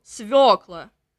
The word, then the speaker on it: свекла: Ударение на ё, свекла.